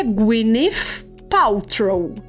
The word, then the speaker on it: Gwyneth Paltrow: Então aprendi que é Gwyneth Paltrow.